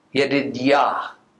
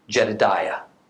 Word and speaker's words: Yedidiah: Very interestingly, we read that God gave Solomon yet another name, Yedidiah, or sometimes we'll pronounce it as Jedidiah.